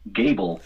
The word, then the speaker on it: Gebel: Another guy, his last name is G-E-B-E-L, and I've heard it Gebel. Gerbil, and it's actually pronounced Gable, and it's like you take your best guess, and you never know.